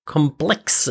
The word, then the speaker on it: QOMPLX: It went away, but apparently it's coming back, and Complex, I think that's how you pronounce the name of the company, QOMPLX, did now reboot Punk Spider and promises to essentially revive the project.